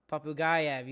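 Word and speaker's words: Popugaev: But Nikita Papugayev, I hope I say that right. – Papugayev. – He's a Russian. Papugayev, you said it right.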